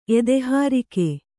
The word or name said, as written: ಎದೆಹಾರಿಕೆ